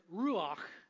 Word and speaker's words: ruach: And to pronounce it, it's kind of like that chesed. It's got that ch sound in Hebrew that is a cross between the C and the H, and you get that guttural sound, and so this one ends with that ruach.